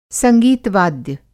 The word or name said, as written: संगीत वाद्य